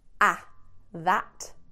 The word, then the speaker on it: that: That and those, completely different vowel sound. Ah, that. Oh, those.